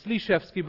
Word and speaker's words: Slyshevsky: But yeah, it's Slyshevsky. But like I said, nobody, not in Germany or America, really knows how to say it.